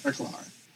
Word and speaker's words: Feklar: At level 110, we have Felker, Tinoch, and, or I should say Fekler. It's hard to pronounce. – Feklar, yeah. – Feklar.